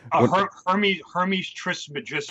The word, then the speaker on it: Hermes Trismegistus: call it the Hermes Trismegistus. Am I saying that correctly? – Hermes Trismegistus.